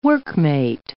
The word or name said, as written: workmate